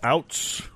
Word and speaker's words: Ouzts: Leonard, how do you pronounce that? O-U-Z-T-S. Outs. Leonard Outs.